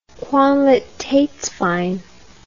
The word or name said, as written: qualitatswein